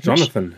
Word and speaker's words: Jonathan: Eure Einschätzung zu, heißt er dann wirklich Jonathan? Das ist immer so eine geile äh, Aussprachentechnik. – Jonathan, ja. Jonathan in dem Fall.